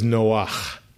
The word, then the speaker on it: Noach: Because his name is not Noah. There is a Hebrew name Noah, but it's not spelled the same way. It means move, like a motor. – Mm-hmm. – And so one of the five daughters of Tzlofchad, and I have no idea how that's pronounced in English, is named Noah. But his name is Noach.